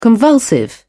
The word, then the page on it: convulsive: British English